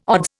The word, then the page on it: odds: Indian